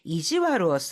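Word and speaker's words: 意地悪: 스키나코니 좋아하는 아이한테 이지와르를 する 좀 일부러 못되게 구는 그런 아이들이 있었잖아요. いましたよね. 스키나코니 고 이지와르를 して 낚았었다리 뭐 네, したり. 자, 이지와르 여기에서는 이 악자를 와르라고 읽고 있습니다.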